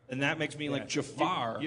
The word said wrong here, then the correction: Jafar: I didn't mean to say it like Christopher Walken, but... – It's Jafar.